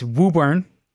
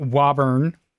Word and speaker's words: Woburn: But it's anybody reading the press release, it's Woburn. It's not Wauburn. It's Woburn. Okay. You got to say it right.